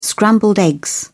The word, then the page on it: scrambled eggs: British English